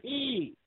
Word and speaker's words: Peay: I only mention this game because I like the name Austin Peay, and I love the chant that the student section break out a lot at their basketball games. You'll always have them in the stands chanting, "Let's go Peay!